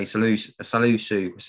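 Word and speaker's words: Salisu: And I'm going to have to say Salusu seems to be... I've said that wrong, haven't I? – No, that's close enough. It's all right.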